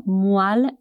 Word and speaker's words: moelle: Moelle se escreve M-O-E-L-L-E. E sim, as letras E e O juntas produzem o som de UA.